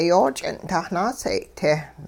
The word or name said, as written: yooch'ing'-tah naaseeyay-te